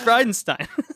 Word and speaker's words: Bridenstine: Was I Bridenstine? – Yeah, dude, read those letters out. Spell that out. It's Bridenstine.